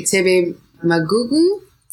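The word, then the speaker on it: Thebe Magugu: You also mentioned, I don't know if I'm pronouncing the name right, Tebe Magugu? – Yeah.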